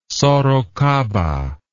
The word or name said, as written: sorocaba